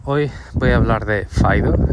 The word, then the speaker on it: FIDO: Fido o Fido, como Em, se pronuncia en inglés.